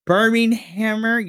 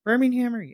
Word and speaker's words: Birminghammer: I'm going to try to pronounce this because I wrote it in my notes correctly because I always get this finisher's name wrong. The Birminghammer. Yeah, the Birminghammer, I think. Birminghammer, yeah. – Birminghammer. – Gosh dang it. – Like a hammer that's on fire.